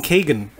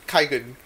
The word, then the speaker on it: Kaigan: Because someone keeps saying Kagan. And I was like, who's... – I pronounce this Kaigan.